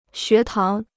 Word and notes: 学堂: xué táng

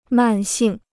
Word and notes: 慢性: màn xìng

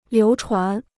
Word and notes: 流传: liú chuán

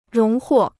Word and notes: 荣获: róng huò

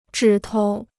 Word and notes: 指头: zhǐ tou

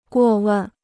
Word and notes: 过问: guò wèn

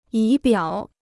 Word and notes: 仪表: yí biǎo

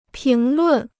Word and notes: 评论: píng lùn

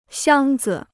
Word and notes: 箱子: xiāng zi

